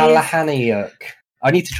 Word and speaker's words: Palahniuk: It's Palahniuk.